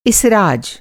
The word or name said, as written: इसराज